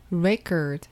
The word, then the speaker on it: record: 当你把它的重音放在第一个音节的时候，就是 record。 Record 它是名词，那当你把重音放在第二个音节的时候呢，就会变成 record。